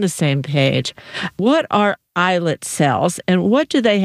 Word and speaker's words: islet: And the term is islet. But it's spelled I-S-L-E-T, as if it's islet or something, but it's not. It's islet.